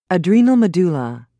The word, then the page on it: adrenal medulla: American English